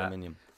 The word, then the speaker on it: Aluminium: How do you say that? – Aluminium. – Oh, aluminium. God.